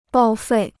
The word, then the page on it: 报废: bào fèi